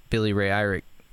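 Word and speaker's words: Billy Ray Irick: Billy Ray Eirick. Am I saying his name correctly? – Yep, you are.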